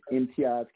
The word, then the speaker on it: Imtiaz: My name is Imtiaz Kabria. That's spelled I, M as in Mary, T-I-A-Z, last name K-I-B as in boy, R-I-A.